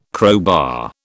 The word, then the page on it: crowbar: British English